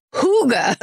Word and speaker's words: hygge: Then Sarah Tunderman wrote in and said, quick correction corner, Higa is pronounced Hooga.